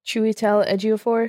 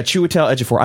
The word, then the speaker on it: Chiwetel Ejiofor: Chiwetel Ejiofor. – Yeah. Chiwetel Ejiofor.